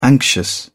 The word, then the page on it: anxious: British English